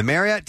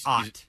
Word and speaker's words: Marriott: And the Marriott team says that if you're pronouncing Marriott correctly, it should actually rhyme with chariot.